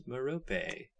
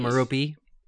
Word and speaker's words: Merope: And yes, listeners, it is pronounced Merope. – Not Merope.